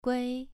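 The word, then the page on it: gui: tone 2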